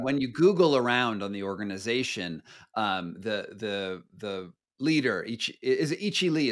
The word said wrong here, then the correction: Ilchi Lee: is that how it's pronounced? I've only seen It's Ilchi Lee. Ilchi.